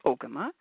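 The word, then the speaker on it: Ogema: And my first question was, how do you pronounce Ogama, which I didn't know. And she said, Ogama, it's an Ojibwe word.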